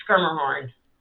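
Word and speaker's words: Schermerhorn: Skirmerhorn.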